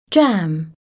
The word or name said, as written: jam